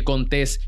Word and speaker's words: Contés: Mi nombre es Miguel Contés, con acento en la E, y este es el podcast donde te ayudamos a conquistar la vida y los negocios para que seas más productivo.